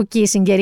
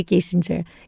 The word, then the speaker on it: Kissinger: Τέλο πάντων, μην τσακώνεστε για το πώ προφέρεται ο Κίσιγκερ ή Κίσιντζερ. Και τα δύο σωστά είναι.